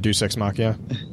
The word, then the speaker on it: Deus Ex Machina: So the options there are: something happened ex deus machina, or how's that? I can't even remember how that's pronounced. Ex Deus Machina. – Oh, the Deus Ex Machina.